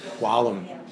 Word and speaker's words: Whalum: You get Whalum. – Yeah, Tom normally, Whalum. In fact, when we lived in France, my name was Whalum, you know.